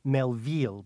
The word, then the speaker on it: Melville: It's a major film by a major director, Jean-Pierre Melville, and I'm not pronouncing it Melville because he borrowed his last name from Herman, and it's silly to Frenchify it.